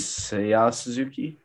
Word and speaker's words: Seiya Suzuki: Seiya Suzuki. – Nice. You totally nailed that. That was 100% accurate pronunciation.